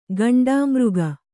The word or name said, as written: ಘೇಂಡಾಮೃಗ